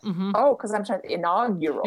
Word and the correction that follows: inaugural: You're trying to put a whole extra syllable in there, babe. It's inaugural.